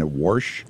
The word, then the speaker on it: Warsh: Fed Chair Kevin Warsh. – Yeah. – I love saying his name so much because that's how I pronounced Wash my whole life.